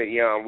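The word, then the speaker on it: Eyone: You can check out everything pertaining to Eon Williams.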